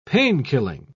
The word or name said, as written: painkilling